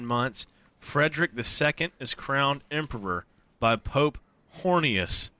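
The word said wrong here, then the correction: Honorius: Okay, it's actually pronounced Honorus, but we're going to call him Hornius.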